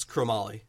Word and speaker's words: chromoly: Yeah, it's chromoly. – It's chromoly. Yep. – Yes, that's in the bike world anyway. That's mm-hmm. the way that it's pronounced.